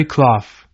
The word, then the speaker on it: Clough: Dear husband, Mary Clough, Mary Clough, rhymes with how, was the daughter of Ellen White's sister Caroline, and I will do all we can to forward the work of my writings.